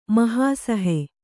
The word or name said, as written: ಮಹಾಸಹೆ